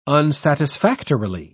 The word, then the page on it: unsatisfactorily: British English